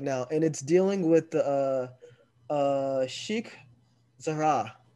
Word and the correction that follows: Sheikh Jarrah: So it's a neighborhood occupied in East Jerusalem called Sheikh Jarrah, right?